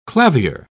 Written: clavier

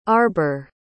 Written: arber

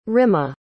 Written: rimma

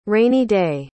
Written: rainy day

